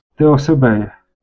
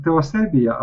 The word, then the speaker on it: Теосебея: Ну, ми, значить так, ми можемо її назвати Теосебія або Теосебея. Ну, як собі хочемо, бо можемо залишатися з давньогрецьким наголосом, а можемо так адаптувати, дещо українізувати, взоруючись на структурно подібні імена, які усталилися в українській традиції. Отже, Теосебея.